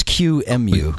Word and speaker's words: QEMU: Q-E-M-U. And I guess it's Q-M-U, right? – Yeah. – Q-M-U, right?